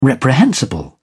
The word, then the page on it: reprehensible: British English